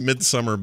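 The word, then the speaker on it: Midsommar: It's pronounced Midsummer, but it's spelled in the way that you would spell it over there, the I guess, I in Sweden.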